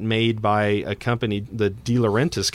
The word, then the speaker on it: De Laurentiis: The De Laurentiis, I think, I'm pronouncing it correctly.